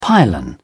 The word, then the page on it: pylon: British English